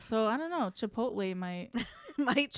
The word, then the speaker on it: Chipotle: I don't even know how to say it wrong. It's Chipotle. – Chipotle. They put the L and the T in the wrong spot.